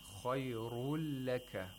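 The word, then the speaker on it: khayrul laka (خير لك): So the nun sakina of the tanween is not pronounced. Instead, you have to pronounce the lam with tashdeed. Khayrul laka. The noon actually merges into the lam. It's like you want to pronounce the noon, but you don't pronounce the noon. Instead, you pronounce one lam.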